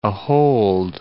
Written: ahold

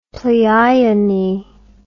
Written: pleione

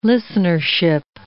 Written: listenership

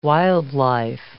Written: wildlife

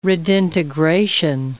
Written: redintegration

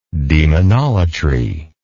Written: demonolatry

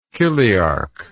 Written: chiliarchy

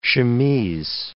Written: chemise

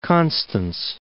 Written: Constance

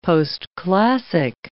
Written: postclassic

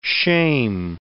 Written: shame

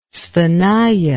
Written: sthenia

